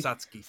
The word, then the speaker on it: Satsuki: Say it for Satsuki. – Me. – Satsuki.